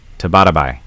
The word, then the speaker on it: Tabatabai: This is Arian Tabata. Uh, am I saying this right? Tabata Tabatabai Tabatabai.